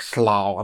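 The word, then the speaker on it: slough: Оно пишется как S-L-O-U GH. – Нет, я болото знаю как swamp. – Ну да, да но вот именно, э, вот есть такое слово, как слау, оно обозначает вот, значит, болотный дом.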